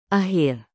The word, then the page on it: akhir: indonesian